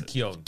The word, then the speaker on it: Keoghan: I think it's like Keoghan. – It's Keoghan. – Oh, is it Keoghan?